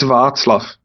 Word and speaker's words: Václav: Am I saying it right, Václav? – No, it's Václav. There is no K.